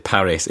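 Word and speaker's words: PVRIS: Next up, a band called, I believe you pronounce it Paris, even though it's P-V-R-I-S.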